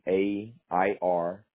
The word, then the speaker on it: Adair: ADAIR, and then it's pronounced Adair, Avenue, Southeast, okay.